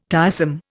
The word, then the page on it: tiresome: Indian